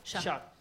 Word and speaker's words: Chat: Chat. Non si pronuncia la T, è terribile, scusami.